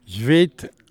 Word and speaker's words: Sveit: Votre reportage s'intitule, alors je ne sais pas si je vais bien le prononcer, Sveit, l'âme de l'Islande. – Sveit, l'âme de l'Islande. Bon, je ne parle pas islandais du tout, mais c'est un peu la prononciation comme le font les Islandais.